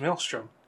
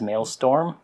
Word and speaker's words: maelstrom: Well, how do you mispronounce maelstrom? – I always think of it as maelstorm.